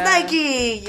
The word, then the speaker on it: Nike: It is Nike. Yes, the wings of Nike, the goddess of victory. – Yes, as my art history professor would pronounce it, Nike.